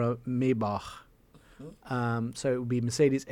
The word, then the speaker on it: Maybach: I have to say it right. Americans say Maybach. It's Maybach. – It's Maybach.